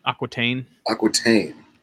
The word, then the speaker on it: Aquitaine: Aquitaine.